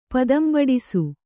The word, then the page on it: ಪದಂಬಡಿಸು: padambaḍisu